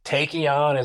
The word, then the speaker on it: Taekion: It's like, is it tachyon? Is it tachyon? And you know, it's got the TAE is the phonetic spelling of a tachyon particle.